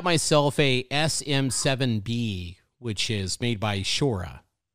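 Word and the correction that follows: Shure: You said Sure or Shura? – Shura or sure, Sure. I always said sure. That's how I've always said it.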